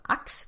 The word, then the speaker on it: Aix: Är det inte lite konstigt att Teresa säger hur Aix ska uttalas, det uttalas Ax tydligen, som hon talar till läsaren?